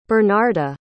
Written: bernarda

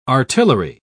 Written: artillery

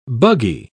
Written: buggy

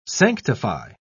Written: sanctify

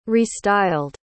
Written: restyled

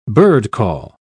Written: birdcall

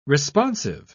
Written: responsive

